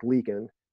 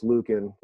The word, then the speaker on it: Leakin: That's why his last name is kind of hard to pronounce, but it's Eric Leakin. And I made the same mistake too. I thought it was Lukin when I saw it.